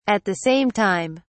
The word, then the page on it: at the same time: British English